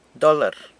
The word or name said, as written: dolr